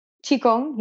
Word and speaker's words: Qigong: And did you, so when you went, oh, I want to learn this, how do you say it? Qigong? – Qigong, yes.